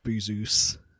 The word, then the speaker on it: Bezos: So the James Bond franchise is now owned by a literal Bond villain in Jeff Bezos, Bozar, Bezos, however you want to pronounce it. – Jeff Bezos.